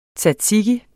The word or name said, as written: tzatziki